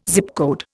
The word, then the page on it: Zip code: Indian